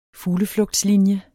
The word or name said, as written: fugleflugtslinje